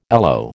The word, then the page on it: alow: American English